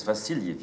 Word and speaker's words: Vasilievich: Well, his middle name is Vasilievich.